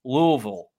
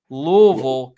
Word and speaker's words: Louisville: Next up, we got the Fighting Sam Hartmans of Notre Dame going against the – now, we got to say it right, ladies and gentlemen – Louisville. Louisville Cardinals.